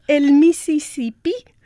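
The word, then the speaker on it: El Misisipí: It was called El Mississippi with an accent on the last I.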